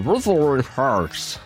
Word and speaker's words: Wuthering Heights: Kate Bush avec son premier single sorti en 1978, Wuthering Heights. C'est comme ça que ça se prononce.